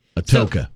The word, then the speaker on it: Atoka: Reba and a friend were touring, like, this historic site in Attica, A-T-O-K-A, when the stairs of the over 100-year-old building started, like, crumbling. – Atoka. So, Atoka. – Yeah. – Is that how you say it? – Yeah.